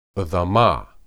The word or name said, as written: edamah